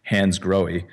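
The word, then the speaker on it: Hansgrohe: The correct pronunciation is Hans Grohe.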